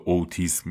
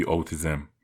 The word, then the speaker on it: اوتیسم: چرا به اوتیسم میگی اوتیزم آقا این اوتیزم هست که شما بهش میگید اوتیسم توی فرهنگ و ادبیات ایران این واژه اوتیسم تلفظ میشه و به اوتیسم مشهوره هیچ اشکالی هم نداره ولی همه دنیا اوتیزم تلفظ میشه میگن اوتیزم لذا من اشتباه تلفظ نمی کنم